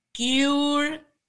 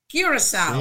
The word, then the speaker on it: Curaçao: So it is Curaçao. Curaçao.